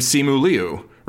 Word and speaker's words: Simu Liu: Names in different languages have different phonemes, and you know, Simu Liu like pronounces his name Simu Liu, right? Which is not the way it's pronounced in Mandarin. It's like Liu Simu, like. Mm-hmm. Is how it's pronounced in Mandarin, but he doesn't say it that way, right?